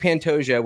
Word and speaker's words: Pantoja: It looks like Pantoja. When I looked it up, just like Duplessis, it said Pantoja. – I think the J is silent. – Yeah, that's how it is in Spanish.